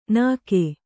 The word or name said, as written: ನಗೆ